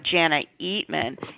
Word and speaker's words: Jana Eatman: Very excited about our guest expert today, Jana Eatman.